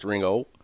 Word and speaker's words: Ringgold: Spell it for me. – R-I-N-G-G-O-L-D. – Ringgold, okay. – But it's pronounced Ringo.